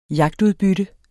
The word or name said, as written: jagtudbytte